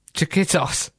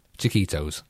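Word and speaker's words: Chiquito's: I think it's Chiquitos. – Chiquitos. Chiquitos. – Oh, is it? – Yeah. – Chiquitos. Is it? – Yes.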